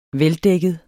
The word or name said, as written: veldækket